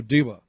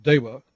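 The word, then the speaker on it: Deewa: It's called Radio Dewa, or is it Dewa? It's spelled D-E-E-W-A, a separate Pashto service for Pakistan, in addition to Voice of America's Pashto service and Radio Free Europe Radio Liberties.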